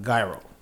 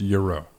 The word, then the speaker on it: gyro: No, us black folks say gyro. – No, it's pronounced euro. – Right, and it's also pronounced. Say what the fuck you want to say. It's called gyro.